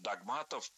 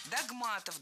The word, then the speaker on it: догмат: Такое ударение в этом слове стало нормативным совсем недавно. А раньше нужно было говорить догмат, догматов. Сейчас узаконена двойная норма. Так что тем, кто всегда говорил догмат, можно не переучиваться.